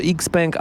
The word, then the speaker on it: XPeng: Jest już XPeng albo XPeng, jak kto woli, po chińsku tak się wymawia tę nazwę, jest BYD.